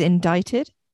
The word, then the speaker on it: indicted: I think it's indicted. – Oh, yeah. There's just the silent C. – Yeah. – Indicted for forgery of a document by the prosecutor's office.